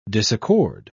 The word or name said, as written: disaccord